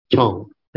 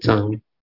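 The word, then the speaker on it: Chang (張): In Mandarin is Chang, which is the same as my surname. Some call it Chong, some call it Chang. Here they pronounce it as Chang. In Hokkien, they call it Tio. Tio. So when you ask, so when they ask you what your surname is, you say Tio. Tio means Chang in Mandarin. In Hakka is Chong. So depending on which dialect, it's pronounced differently.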